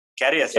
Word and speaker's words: Karius: If we're really looking for an alternate option, I guess we could put up I'm not sure how this is pronounced, Karius. – Karius. – Oh, Karius, yeah.